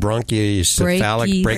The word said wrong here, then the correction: brachycephalic: right? Yeah. Did I say it right, Dr. Demme? – Pretty close. Brachycephalic. We could say pug-like, you know, that always works.